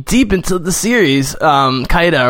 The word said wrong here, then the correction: Cahir: Well, Cahir is the official pronunciation.